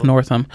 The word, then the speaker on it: Northam: Yep, Ralph Northam. – Is how you pronounce his last name, I think.